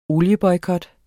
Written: olieboykot

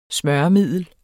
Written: smøremiddel

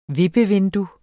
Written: VIPPEVINDUE

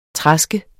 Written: traske

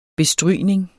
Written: bestrygning

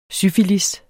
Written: syfilis

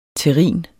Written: terrin

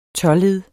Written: tåled

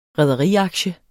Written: rederiaktie

